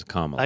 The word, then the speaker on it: Kamala: I do want to talk a little bit about, is it Kamala Harris? Am I saying it right? I never can figure out how we're supposed to say it. – Communist. – It's what now? Kamala. Charlie says Kamala.